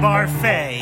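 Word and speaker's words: Barfée: Returning after last season's tragic setback, William Barfy. – It's pronounced Barfay. There's an accent ago.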